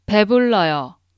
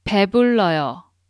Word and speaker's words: 배불러요: It's 배불러요. I'm gonna repeat it 3 times. Listen carefully. 배불러요. 배불러요. 배불러요.